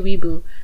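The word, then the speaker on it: weeaboo: Am I pronouncing it wrong? Is it Wee? – No, it's definitely Weeboo. But I don't know where this Wee is from.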